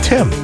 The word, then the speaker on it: Tim: What's wrong with Tim? Rhymes with Jim.